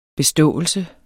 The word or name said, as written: beståelse